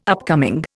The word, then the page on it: upcoming: Indian